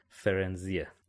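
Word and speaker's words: Firenze: یه جوریه که آدم احساس میکنه باید فایر بخونه، ولی در اصل اسمش فرنزیه که شما تو کتاب صوتی هم گوش بدی همین فرنزی خونده میشه.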